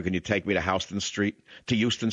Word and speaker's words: Houston: Let's go to Houston, Texas. Some call it Houston, but it's Houston. It's still called Houston, even though in New York they call it Houston Street.